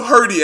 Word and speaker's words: Hirte: But it's pronounced Hirte. Even then, we're not quite sure.